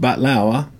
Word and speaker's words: baklava: But there's no use in crying over spilt batlawa, which is baklava. It's actually pronounced in the Middle East, some parts of the Middle East.